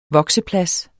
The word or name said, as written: vokseplads